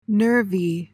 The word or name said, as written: nervy